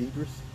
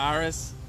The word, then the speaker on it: Idris: I think we pronounce it Idris. – Idris? We? – We as in black folks. We have... – I'm not Iris. – It's Idris. Okay. How you pronounce it? – Iris. – It has a D in it.